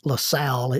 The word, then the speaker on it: Lacelle: His name is Guy LaSalle. It's spelled Guy, LaCelle.